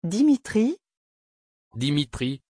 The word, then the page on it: Dimitri: French